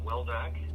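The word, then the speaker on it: Wildeck: I'm Steve Woldeck. That's W-I-L-D-E-C-K.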